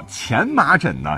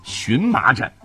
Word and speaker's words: 荨麻疹: 比方说，呃，说服，我们之前经常说说服某人就变成了说服；还有精米变成了梗米，叫前麻疹呢，现在变成了荨麻疹；证据确凿啊，现在就读成证据确凿；还有矮板啊，这个人笨笨的啊，很矮板，现在要读成呆板。